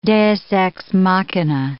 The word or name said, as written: deus ex machina